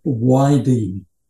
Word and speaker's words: Weide: So the filmmaker, Bob Weide, spelled W-E-I-D-E, and I thought perhaps it was Bob Vide or Bob Weed. – Wade. – I found him on introducing himself on YouTube as Bob Weide.